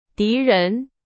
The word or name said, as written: 敌人